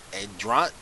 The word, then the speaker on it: Andrade: Andrade.